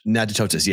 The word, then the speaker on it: Natchitoches: Natchitoches? – Natchitoches, yes.